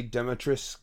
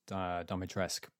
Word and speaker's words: Dimitrescu: Is Lady Demetrisk? Is it Demetrisk? – The game pronounces it Domitresk, but I believe it's actually supposed to be Dimitrescu. But I think the game pronounces it wrong, is what I've heard.